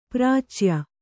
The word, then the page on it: ಪ್ರಾಚ್ಯ: prācya